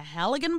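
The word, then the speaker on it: Halligan: He was bludgeoned in the face with a Heligan bar. Is that how you pronounce that? – Something like that.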